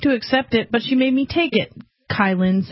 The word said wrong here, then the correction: Kaylin: said. Kaylin. Her name's spelled weird. That's why I keep pronouncing it.